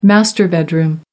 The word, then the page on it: master bedroom: American English